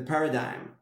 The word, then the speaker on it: paradigm: And it's counterintuitive because of the paradigm, right? The paradigm, I guess. Is that a correct way to say it? Paradigm? Yep. Yeah, Spanish and English. Paradigm. That I need to work as hard as I can for 24/7, not have a life if I want to be successful.